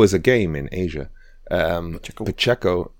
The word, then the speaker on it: Pacheco: Would you pronounce it like that? Pacheco. – Pacheco. Pacheco. You're throwing an N in there. – Oh, sorry, Pacheco.